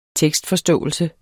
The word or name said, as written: tekstforståelse